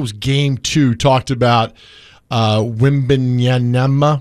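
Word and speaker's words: Wembanyama: So Wimbenyama. – Yeah, that's good. – Wimbenyama.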